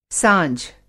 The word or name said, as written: साँझ